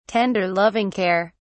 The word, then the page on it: tender loving care: British English